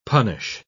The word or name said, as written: punish